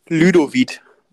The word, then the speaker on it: Ludovic: Aber es ist die basalei die, ähm, wie wird er ausgesprochen? Ludwig? Ludovic. Ludovic genau.